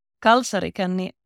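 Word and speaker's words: kalsarikänni: Kalsari Kani. The stress is always on the first syllable in Finnish. And if you see a double consonant, that's a long consonant.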